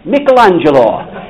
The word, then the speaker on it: Michelangelo: Michelangelo.